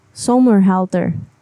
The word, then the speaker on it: Somerhalder: Somerhalder.